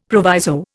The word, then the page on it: proviso: Indian